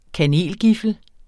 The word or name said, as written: kanelgiffel